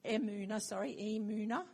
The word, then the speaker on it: Emunah: El Emunah, that's the word I've been, Emunah, said it wrong.